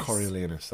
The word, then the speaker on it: Coriolanus: and we saw Coriolanus or Coriolanus. How do you say it? – The word anus is in it. It's funny. and Charles Dance was Coriolanus. – Coriolanus, that's it. Yeah.